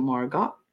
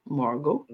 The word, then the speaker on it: Margaux: I was like, is it Margox? Margo? – No. – Margo? Oh, Margo? But it's Margo. Gotcha.